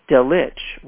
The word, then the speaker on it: Delitzsch: Delitzsch wrote, and that's spelled D-E-L-I-T-Z-S-C-H.